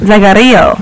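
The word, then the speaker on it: Zaggaro: Zagarillo.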